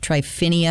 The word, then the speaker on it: Tryphena: Tryphena and Tryphosa? – Yes, I was assuming that's how you say it.